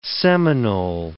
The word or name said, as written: Seminole